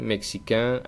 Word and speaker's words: mexicain: Primero, los que acaban en AIN, que se pronunciará en el masculino. Por ejemplo, américain, mexicain, africain, marocain, cubain. El femenino, en cambio, no tiene ese sonido nasal.